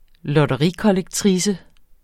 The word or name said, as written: lotterikollektrice